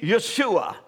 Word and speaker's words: Yeshua: And its original form would be Yeshua, which means Jehovah Savior.